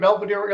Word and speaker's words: Belvidere: You still live in Belvidere, Illinois. Am I saying that right? – Yeah, our shop is up here kind of by Poplar Grove.